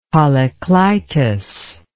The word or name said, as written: polyclitus